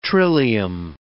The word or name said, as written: trillium